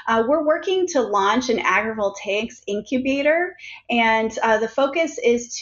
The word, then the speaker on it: Agrivoltaics: It's called, and correct me if I get this wrong, the Midwest Agrivoltaics for Resilient Communities Initiative.